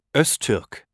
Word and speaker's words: Öztürk: Jutta schreibt man J-U-T-T-A und Albrecht, meinen Nachnamen, A-L-B-R-E-C-H-T. Drei. – Und wie heißt du? – Yasar Öztürk. – Kannst du das buchstabieren? – Ja. Yasar schreibt man Y-A-S-A-R und Öztürk Ö, also O-Umlaut, Z-T, U-Umlaut, R und K.